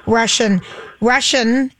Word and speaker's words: Rushin: We're with Steve Rushin.